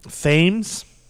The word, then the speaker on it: Thames: Thames.